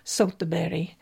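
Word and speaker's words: Söderberg: And I did look up the pronunciation of those two women's last name because I didn't want to butcher it. I always do that on the show, and I thought, the internet's a wonderful thing. So I found the pronunciation, Soltaberi.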